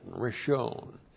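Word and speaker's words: Rishon (ראשון): R-I-S-H, long O-N, Rishon.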